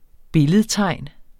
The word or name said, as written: billedtegn